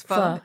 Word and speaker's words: pho: It is pho, though it's spelled pho.